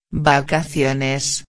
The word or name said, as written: vacaciones